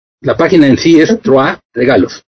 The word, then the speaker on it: Trois: Se escribe Trois. Así, T-R-O-I-S. Que en francés, más o menos se pronuncia Trois. Que es tres en francés.